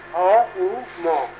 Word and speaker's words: ওম: ওম ওম, যাকে আমরা চক্র বলে দিচ্ছি ওম, কিন্তু ওর উচ্চারণ ওরকম নয়। ওম ওম, এই তিন অক্ষর নিয়ে ওম শব্দের উৎপত্তি। অ উ ম, এই তিন অক্ষর নিয়ে ওম, এই উচ্চারণ।